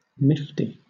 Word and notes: nifty: British English

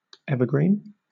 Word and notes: evergreen: British English

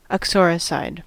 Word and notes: uxoricide: American English

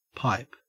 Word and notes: pipe: Australian English

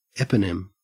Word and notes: eponym: Australian English

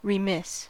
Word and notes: remiss: American English

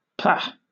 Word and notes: pah: British English

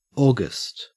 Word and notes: august: Australian English

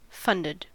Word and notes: funded: American English

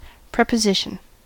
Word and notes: preposition: American English

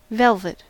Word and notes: velvet: American English

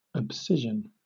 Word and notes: abscission: British English

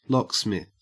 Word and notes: locksmith: Australian English

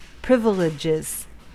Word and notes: privileges: American English

